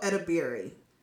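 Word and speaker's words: Edebiri: Played by Ayo Abadiri. Is that how you say it? – I've never... – Ayo... – I don't think I've ever heard of this person. – She's so good. Yeah, Ayo Edabiri.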